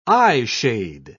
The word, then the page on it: eyeshade: British English